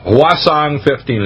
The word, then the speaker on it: Hwasong: That's spelled Hwasong 15 is how you pronounce it.